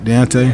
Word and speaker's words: Deontay: Deontay? – Yes.